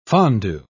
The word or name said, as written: fondue